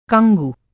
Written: कंगु

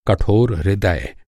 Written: कठोर

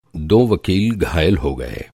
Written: वकील

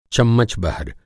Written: चम्मच

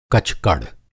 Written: कचकड़

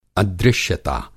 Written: अदृश्यता

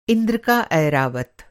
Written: ऐरावत

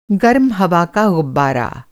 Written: गर्म हवा का गुब्बारा

